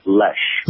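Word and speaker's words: Loesch: But his replacement is a young female, moderately conservative, Dana, I never can pronounce, Lash, I believe is how you pronounce her last name. – Her name is pronounced Lesh. – Lesh. Dana Lesh. L-O-E-S-C-H, I believe.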